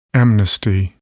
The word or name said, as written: amnesty